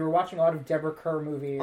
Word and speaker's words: Deborah Kerr: That was her, like, MGM Welcome to Hollywood campaign that they said, like, Deborah Kerr, her name rhymes with star.